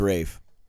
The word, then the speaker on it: Ralph: That's Ralph Fiennes. – No, it's Rafe. They call him Rafe.